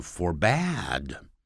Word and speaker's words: forbade: He forbid B-I-D、啊、但是如果变成过去时叫 forbad、啊、 F-O-R-B-A-D-E 有人念成 forbade 但是另外也念 forbad 好怪的发音但是那不怪哦是我们很少听到其实很多老外都念 forbad